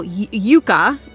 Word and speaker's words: Youka: Laylee's foal, Yuka, it's spelled Y-O-U-K-A,